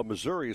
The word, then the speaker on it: Missouri: Alan, just so you know, Missouri is pronounced Missouri, not Missouri.